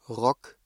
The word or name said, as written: Rock